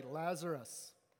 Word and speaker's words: Lazarus: I know that's shocking for many of you, but am I saying it right? Lazarus. Good, got the thumbs up there.